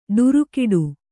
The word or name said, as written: ಡುರುಕಿಡು